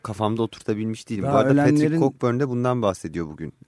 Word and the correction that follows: Cockburn: Cockburn okunuyor. – Cockburn doğru. Hı. Özür dilerim.